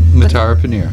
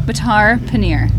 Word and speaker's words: Matar Paneer: Matar Paneer. – Matar? – Yeah. – Matar Paneer.